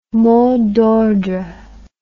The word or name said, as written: mot d'ordre